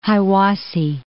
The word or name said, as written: Hiwassee